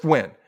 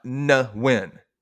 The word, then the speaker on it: Nguyen: And he said his name is pronounced Nguyen. – I've heard when. – Just when. – Yeah. – Not Nguyen, which is how they say Tina's last name.